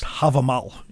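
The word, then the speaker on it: Hávamál: which I think is pronounced Havamal in the original kind of name for it.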